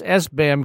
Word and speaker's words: SBAM: The different entities that are associated with SPAM. – We should correct you. It's pronounced SBAM because SPAM is something quite different.